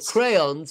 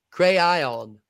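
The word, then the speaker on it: Craiyon: It's called Crayon, spelled with an AI. Crayon.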